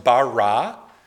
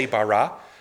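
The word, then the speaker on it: bara (ברא): The verb in Hebrew for create is pronounced, let's all say it with me, or after me rather, it's pronounced bara. Let's all say bara. Bara, that's the word for create.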